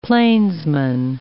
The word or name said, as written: plainsman